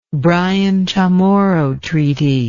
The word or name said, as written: Bryan Chamorro Treaty